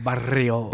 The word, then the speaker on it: Barrio: No, not Barrio. Barrio.